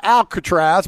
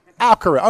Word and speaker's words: Alcaraz: Not Alcatraz, but Alcaraz.